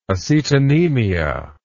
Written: acetonemia

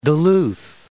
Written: Duluth

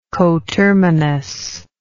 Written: coterminously

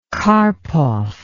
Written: karpov

